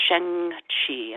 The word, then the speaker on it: sheng chi: which is creating what we call sheng qi, and that's spelled S-H-E-N-G-C-H-I.